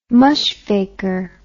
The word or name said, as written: mushfaker